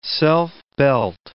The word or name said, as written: self-belt